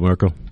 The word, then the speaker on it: Merkel: Angela Merkel.